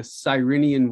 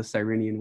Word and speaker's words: Cyrenian: It's the Cyrenian Way: mm-hmm. – uh, Spiritual Poverty and the Art of Accompaniment. So the Cyrenian Way.